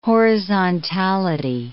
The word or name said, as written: horizontality